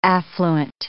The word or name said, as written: affluent